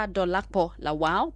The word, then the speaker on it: Dolapo Lawal: The messages are by Pastor Dolakpo Lawal. Pastor Dolakpo Lawal. And it's on his YouTube channel. His name is spelled D-O-L-A-P-O. L-A-W-A-L, Pastor Dolakpo Lawal.